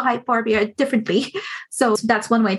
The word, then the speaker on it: Hyperborea: One of many, the one about, oh gosh, I always pronounce it wrong. So that's where you corrected for me. Hyperborea? – Hyperborea. – Hyperborea, thank you.